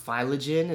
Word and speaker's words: Phylagen: I think it's pronounced phylogen. It's spelled P-H-Y-L-A-G-E-N.